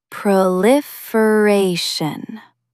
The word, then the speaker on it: proliferation: Stress the fourth syllable. Proliferation. Say it aloud.